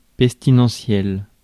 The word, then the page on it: pestilentiel: /pɛs.ti.lɑ̃.sjɛl/